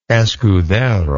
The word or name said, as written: escudero